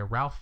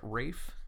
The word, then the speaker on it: Ralph: Which, sure, Ralph has, you know, the chops to play someone who's torturing children. – Is it Rafe? – Is it? – I think that's how he pronounces it.